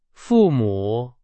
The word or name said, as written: 父母